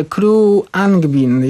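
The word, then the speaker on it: Khruangbin: Kolejny zespół, nazwę kolejnego zespołu uczyłem się długo wymawiać i w końcu stanęło i tego się trzymam. Kru Angbin. – Thank you. – Więc będę trzymał się tej wersji, że Crew Angbin,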